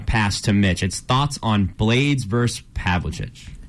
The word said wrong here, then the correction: Pavlovich: Is that how I pronounce it right? – It was Pavlovich. – Pavlovich, gotcha.